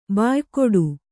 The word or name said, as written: ಬಾಯ್ಗೂಡು